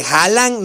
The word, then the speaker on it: Haaland: Haaland, ¿cómo le mencionas? Haaland no se menciona como Haaland, se pronuncia Haaland. Erling Haaland, no se pronuncia Haaland. La doble A va como O, según lo que leí.